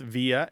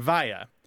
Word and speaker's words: via: The second thing is there are two standard pronunciations of this word, and they overlap greatly, and it's not altogether clear to anyone which one is the most correct at all times. Throughout the English-speaking world, people say both via and via. There is a general kind of separation of the two pronunciations. You probably say via if you're using the preposition.